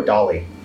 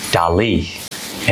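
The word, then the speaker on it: Dalí: Warhol or Dali? – Dali. And by the way, it's pronounced like that with the I, D-A-L-I, Dali.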